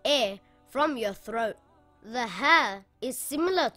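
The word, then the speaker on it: Ha (ه): Ha is a light letter and should not be pronounced heavy. Ha makes a similar sound in English to the letter H in the word hat or the word horse.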